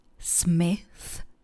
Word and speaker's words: Smith: Pozor na výslovnost konečné hlásky ve slovíčku Smith. Je to úplně stejná hláska, s jakou se setkáte ve vazbě thank you.